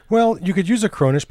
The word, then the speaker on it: Acronis: And it's spelled A-C-R-O-N-I-S, Acronis.